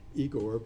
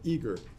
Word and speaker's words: Igor: And by the way, that's not pronounced Igor, but Eager, as in E-A-G-E-R, Eager.